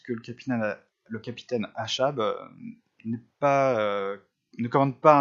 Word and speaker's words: Achab: Alors je prononce Achab, je ne sais pas, les Anglais ne prononcent pas Achab, euh, je vais prononcer comme ça, ça s'écrit A-C-H-A-B, en anglais il n'y a pas le C.